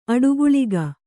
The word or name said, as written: ಅಡಿಗಲ್ಲಿಕ್ಕು